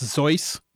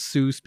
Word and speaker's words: Seuss: Seuss is actually pronounced Zeus in German, but Seuss said that he liked that people thought of it as Seuss because it put him close to Mother Goose. So when you're saying Seuss, you're saying it correctly, but the German pronunciation is actually Zeus.